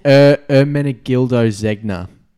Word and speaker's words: Ermenegildo Zegna: It's Ermenegildo Zegna.